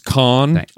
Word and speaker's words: Cannes: And then, you know, a little bit of a hand clap at Cannes Festival, he's going to lend some credibility. – It's pronounced Cannes, actually.